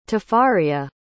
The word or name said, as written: tafariah